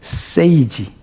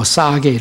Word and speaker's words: sage: sage, s-a-g-e, sage. 뭐 싸게 이렇게 발음하는 사람도 있지만, 네.